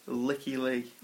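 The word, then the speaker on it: Lykke Li: Now I've checked this out on YouTube, a pronunciation guide to her name. It is Licky Lee.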